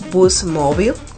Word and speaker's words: Boost Mobile: Y a partir del 2 de febrero, todos los usuarios de Virgin Mobile serán movidos a nuestra marca hermana Boost Mobile o Boost Mobile, que es como realmente lo pronuncian, ¿verdad?